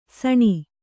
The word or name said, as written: ಸಣಿ